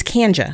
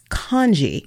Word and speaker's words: Kanji: So, for example, if someone reads my name and then mispronounces it and it's like, oh, is that, it's Kanja. I said, no, it's Kanji.